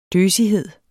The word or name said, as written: døsighed